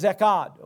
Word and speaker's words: echad: In the Deuteronomy account, in other words, the Old Covenant, or the Greek word, or probably the Hebrew word for that, is echad, okay, E-C-H-A-D, echad.